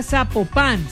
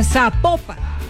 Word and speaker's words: Zapopan: Oiga, nos escriben los oyentes y que no se pronuncia Zapopan, sino que se pronuncia Zapopan.